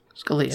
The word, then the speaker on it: Scalia: Scalia. – Scalia. – Scalia. I pronounced it. As soon as I said it, I'm like, that's wrong.